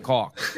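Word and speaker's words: caulk: And listen, if you pronounce it with a C A U L K, you're fine. Cock.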